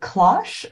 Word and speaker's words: cloche: Hey, how do you pronounce this word? Is it cloche or cloche? – Cloche.